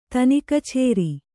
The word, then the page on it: ತನಿಕಛೇರಿ: kannada